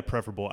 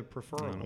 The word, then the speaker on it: preferable: You say preferable, because some people say preferable. – Well, yeah, preferable. I misspoke. – Oh, okay. Well, you're a smart guy, so I thought, oh, I never heard the preferral.